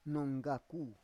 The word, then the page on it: nonggaku: Roon